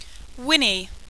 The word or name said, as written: whinny